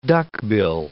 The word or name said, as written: duckbill